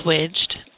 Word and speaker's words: Swigchd: Well, it's pronounced swidged, and it means to switch or change your look. So it's spelled S-W-I-G-C-H-D, and the W-I-G is red because we want the word wig to pop out.